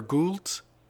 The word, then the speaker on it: Gould: Is it Gold or Gould? – Oh, Stephen J. Gould, yeah.